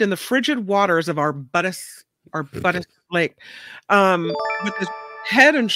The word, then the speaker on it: Arbutus: Arbutus. A-R-B-U-T-U-S. Arbutus.